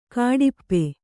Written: ಘಾಟುಪ್ಪು

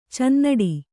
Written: ಚುನ್ನಾಟ

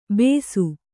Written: ಬೆಸು

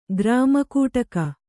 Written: ಗ್ರಾಮಕೂಟಕ

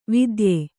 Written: ವಿದ್ಯೆ

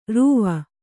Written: ರಾವು

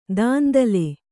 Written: ದುಂದುಲು

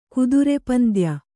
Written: ಕುದುರೆಪಂದ್ಯ

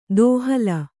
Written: ದೋಹಿಲ್

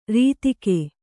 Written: ರತಿಗೈ

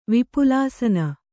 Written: ವಿಪುಲಾಸನ

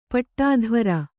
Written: ಪಟ್ಟಾಧ್ವರ

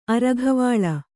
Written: ಅರಘವಾಳ

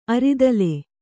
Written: ಅರಿದಲ

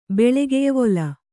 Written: ಬೆಳೆಗೆಯ್ವೊಲ

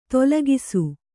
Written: ದಾಲಕ್ಷ

